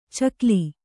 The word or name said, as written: ಚಿಗಿಲ್